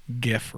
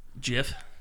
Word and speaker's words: GIF: People who pronounce GIF wrong. – Jif? – GIF. – Jif? – I will fucking fight you.